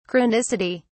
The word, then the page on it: chronicity: British English